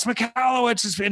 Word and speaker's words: Michalowicz: And A, they learn how to pronounce my name properly because he sticks it in there and says, okay, it's pronounced Michalowicz, but it's still stupid or whatever.